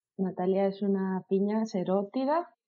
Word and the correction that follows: serotina: ¿Lo he dicho bien? – Serotina. – Serotina, sí.